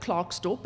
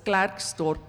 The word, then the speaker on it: Klerksdorp: I don't call it Clarksdorp, it's Gladksdorp means, huh? So, uh, just getting this announce, pronunciation correct, the first time I've been to Klerksdorp,